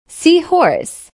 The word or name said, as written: seahorse